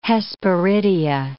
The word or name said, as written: hesperidia